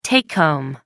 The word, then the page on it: take home: American English